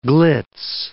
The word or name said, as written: glitz